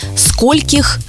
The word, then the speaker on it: скольких: Вообще-то там все правильно до скольких, здесь только вот с ударением нужно поставить меточку, что скольких, сколькими, о скольких, и у слова «сколько» нет падежной формы «скольки».